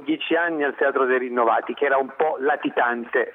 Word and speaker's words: Rinnovati: Rinnovati Rinnovati. Credo di aver giocato bene sull'accento, ma lo domandiamo a chi l'ha ideato, ed è responsabile progetto cittadinanza studentesca per l'Università.